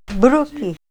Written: bruki

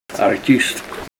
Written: art'ist

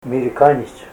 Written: mirikanyits

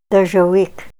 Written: dozhawik

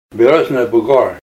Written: Birch Hump

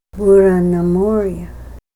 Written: bur'a na mor'a

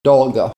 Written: dolga

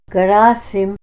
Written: Grassim

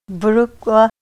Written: br'ukwa

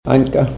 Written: aynka

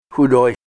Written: hudoy

